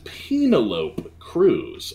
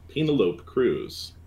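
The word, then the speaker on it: Penélope Cruz: I don't mean to correct you on the pronunciation, but I believe it is Penelope Cruz, actually.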